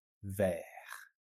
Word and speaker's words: vert: Bleu, noir, violet, orange, vert, silent T. Le T, il est silencieux.